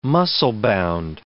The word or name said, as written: muscle-bound